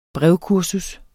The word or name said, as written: brevkursus